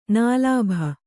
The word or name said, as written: ನಿಲಾಭಿ